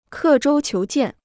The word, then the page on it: 刻舟求剑: kè zhōu qiú jiàn